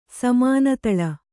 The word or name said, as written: ಸಮಾನತಳ